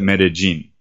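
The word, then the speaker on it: Medellín: Medellin Colombia, uh, anytime you have a double L in this region, in this accent uh, of the people that live here, they, they pronounce it as a J sound rather than a L, like a Y sound, like okay. in classic Spanish. So they call it Medellin.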